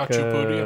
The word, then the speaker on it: khachapuri: It's like khachapuri, am I saying it right? – Yeah, khachapuri.